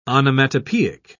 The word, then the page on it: onomatopoeic: British English